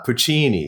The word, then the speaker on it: Puccini: yeah, Puccini.